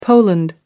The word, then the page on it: poland: American English